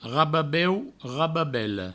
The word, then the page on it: rababeu, rababela: provençal; rababeu, rababello